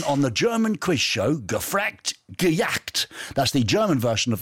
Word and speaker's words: Gefragt, Gejagt: It's called Gefragt, Gejagt. – Gefragt, Gejagt. – Yes, it means asked and chased, but it rhymes in German.